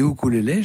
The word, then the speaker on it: ukulélé: Au fait, ça se prononce comment On dit ukulélé, je crois, à la mode hawaïenne.